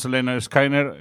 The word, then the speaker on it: Lynyrd Skynyrd: Pues Leonard Skinner, es como se pronuncia,